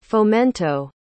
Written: fomento

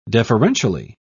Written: deferentially

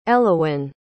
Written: Elowen

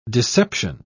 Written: deception

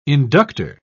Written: inductor

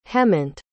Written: hemant

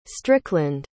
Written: strickland

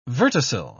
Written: verticil

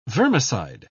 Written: vermicide

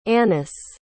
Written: annice

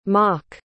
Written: mak

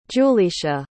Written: julisha

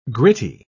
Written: gritty

